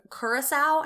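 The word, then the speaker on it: Curacoa: It's being escorted by a smaller ship, the HMS Curacao, I think is how you say it. – Yep, that sounds right.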